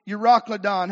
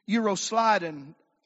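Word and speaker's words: Euroclydon: And we call it, oftentimes it's read and pronounced as Euroclodon. However, the people that read the Bible through, that you can listen to sometimes, that are supposed to be smarter than us and know how to pronounce things, they pronounce this Euroslidon rather than Euroclodon. So ever how you would prefer to pronounce it, that seems to be the correct pronunciation.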